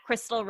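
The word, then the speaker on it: Khrystle: You can find me on Instagram at Crystal Ray. So my name is spelled super complicated. It is K-H-R-Y-S-T-L-E-R-E-A.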